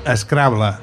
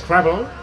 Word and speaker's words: Scrabble: Sembla que hi ha doncs, dues maneres, diguem, que són les més properes a la llengua catalana, que és Scrabble o Scrabble. – Scrabble, no? – Exacte. La pronunciació anglesa és Scrabble, Scrabble. – Però, sona una miqueta preciosa, diguem, si o artificial, diguem-ne. – Si et sembla, direm Scrabble, no? Que és com si diguéssim Shakespeare, no?